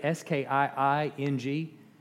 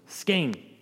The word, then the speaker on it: skiing: A lot of people say S K I I N G with just one syllable, sking, but it's skiing, right?